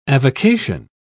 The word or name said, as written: avocation